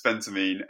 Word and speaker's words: Phentermine: The actual drug name is Phentermine, which I kind of keep alluding to, keep saying it wrong as well. But it's Phentermine.